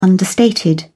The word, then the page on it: understated: British English